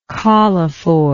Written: collophore

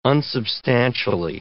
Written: unsubstantially

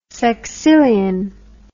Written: sexillion

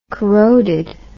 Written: corroded

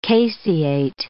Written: caseate

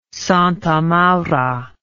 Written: Santa Maura

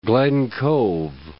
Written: Glen Cove

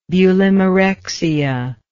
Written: bulimarexic

